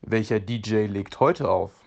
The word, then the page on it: auflegen: German